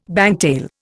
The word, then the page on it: bangtail: Indian